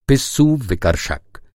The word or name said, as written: विकर्षक